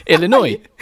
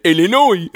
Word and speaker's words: Illinois: That's how it's said. Illinois. – You sound so... Illinois.